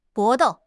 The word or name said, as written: 搏斗